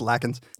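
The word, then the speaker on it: Lakens: And I think it was you that said, like, they're trying to cancel Daniel Lankins they're piling on Daniel Lankins because of a tweet. – Right. Right. So I've learned that I'm pronouncing it wrong. It's Lackins.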